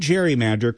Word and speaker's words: gerrymander: But when the term gerrymander caught on, it wasn't with the hard G sound of Gary's surname.